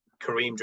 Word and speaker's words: Karim: On LinkedIn, Kareem Jernit. – That's J-E-R-N-I-T-E. – J-E-R-N-I-T-E. Kareem, K-A-R-I-M.